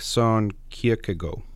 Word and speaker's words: Kierkegaard: We say Soren Kierkegaard when we say it in English, but it's more like Son Kierkegaard, something like that.